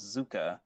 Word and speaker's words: Zukka: Um, the next one is, uh, I believe this is the way that you like me to pronounce it, is Zukka, which is the combination of Zuko and Sokka.